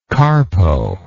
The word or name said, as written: carpo